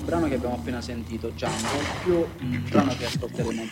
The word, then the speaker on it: Django: Jungle, la D è muta. – What's your name? – Django. The D is silent.